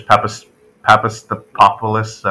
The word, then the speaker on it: Papastathopoulos: Yeah, for former Greek standout Socrates Papastathopoulos.